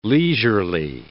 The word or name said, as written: leisurely